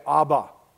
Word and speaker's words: Abba: The Aramaic word was the word Abba. It's spelled A-B-B-A. And when Jesus uttered the words, this is actually what he would say. This is actually how it would sound. He would say, Abba, Abba.